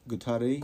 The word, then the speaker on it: Guattari: Was it Deleuze and Guattari? Guattari, yeah. We just learned how to pronounce his name.